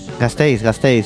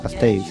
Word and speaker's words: Gasteiz: Pero bueno, ya tenemos Hack Lab en Gasteiz. ¿Cómo se dice así? – Gasteiz, sí, como si dices gastar, Gasteiz.